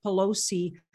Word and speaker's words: Pelosi: I get that they're part of a group that, or they got contacted after they got, I get that Pelosi, did I say her name right? – Yes. – I always say her name wrong.